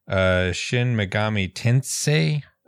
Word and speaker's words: Shin Megami Tensei: Shin Megami Tensei. I may be pronouncing that wrong. – No, you got it.